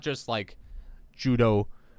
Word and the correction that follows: Judeo: Oh shit. – Judeo? – Yeah, Judeo-Christian. That's how you say it, right?